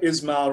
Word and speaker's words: Ismail: Well, what's nice is that Ismail rhymes with freestyle, so you already, you know, you got some.